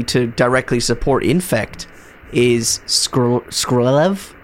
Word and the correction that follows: Skrelv: Skrelv, Defector Mite.